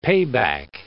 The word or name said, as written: payback